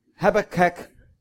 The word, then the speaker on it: Habakkuk: How do you say that in Polish? – Habakuk. – Habakuk. Habakuk. Mango. Okay. Habakkuk is the way we say it in Australia. Habakkuk. – Habakkuk should be, really.